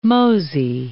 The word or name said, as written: mosey